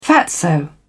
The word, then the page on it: fatso: British English